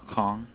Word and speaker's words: Kang: Mike Kong. – I will spell it M-I-K-E. – M-I-K-E. – Yes. – K. – K-A-N-G.